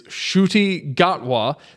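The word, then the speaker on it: Ncuti Gatwa: His name is Shruti Gatwa. That's how you pronounce his name, folks.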